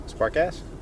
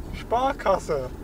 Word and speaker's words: Sparkasse: Is it really offensive if I say spark ass because that's how it's spelled? You have to pronounce it the way you just said? – That's how it is pronounced.